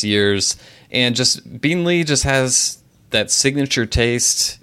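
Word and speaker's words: Beenleigh: that we in the past had a habit of mispronouncing quite a bit. So I'm going to try to pronounce it correctly when I say that I'm going to Beanley Distillery in Australia, and I'm selecting the Transcontinental Rum Line Australia 2013 6-year aged Beanley rum from Australia.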